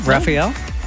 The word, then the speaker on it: Raphael: Raphael? – You got it!